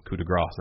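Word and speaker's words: coup de grâce: And I think the latter is correct because coup de grace would be G-R-A-S. – S, yeah. – Whereas it's not spelled that way. It's spelled like grace.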